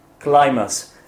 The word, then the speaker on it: climbers: Sorry, the pronunciation is climbers. Actually, there is B letter when you spell it when you write it. – It must be silent. – It's a silent letter, so you say climb. – Climbers. – Climbing. Climber. Right? There is no B sound.